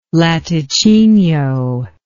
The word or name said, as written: latticinio